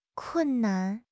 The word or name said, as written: kun4 nan5